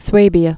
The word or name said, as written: Swabia